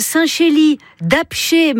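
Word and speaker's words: Saint-Chély-d'Apcher: Vous avez un Saint-Chély d'Apché, mais alors ça s'écrit avec un R, mais on ne prononce pas le R.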